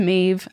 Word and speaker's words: Maeve: Maeve starts, "Hey, spirits team, it's Maeve." I also appreciate Maeve that says pronounced like cave, which is very Hell funny yeah.